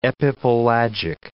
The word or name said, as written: epipelagic